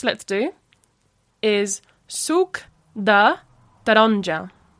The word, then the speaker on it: taronja: Yeah, sometimes people miss out, so it's spelled T-A-R-O-N-J-A, but sometimes people miss out that A after the T, so they say taronja. Yeah, taronja. Taronja is technically how you should say it.